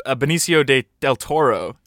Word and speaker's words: Benicio del Toro: Yeah, I went up to Benicio del Toro.